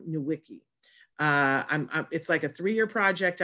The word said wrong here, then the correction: Nweke: You swallow the N, so it's Ifu Nanya Nweke.